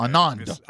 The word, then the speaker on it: Anand: Anand. – Anand, yeah.